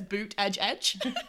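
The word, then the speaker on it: Buttigieg: On his TikTok, he has like how to pronounce my name, and it literally just says Boot Edge Edge.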